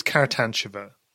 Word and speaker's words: Karatantcheva: I, mean, I think it, I, th- I, think it's Karatantcheva. Um, so I've just done it wrong as well.